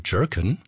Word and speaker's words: Jurken: Jerkin. Der Musiker aus Illinois schreibt seinen Nachnamen so, wie laut landläufiger Meinung in Berlin wohl der Plural der Gurke ausgesprochen wird, also J-U-R-K-E-N.